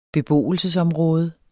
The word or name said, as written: beboelsesområde